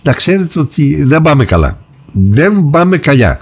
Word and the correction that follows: καλά: Συγγνώμη, καλά.